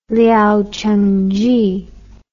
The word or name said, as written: liao cheng zhi